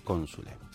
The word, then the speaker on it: consule: Lo cierto, nos dice el autor de esta web, es que la letra C en latín se pronunciaba siempre como una K, independientemente de la vocal que le siguiera. Así pues, la expresión del consulado de Cicerón, aunque nosotros digamos Cicerón, sería Quiquerone Cónsule.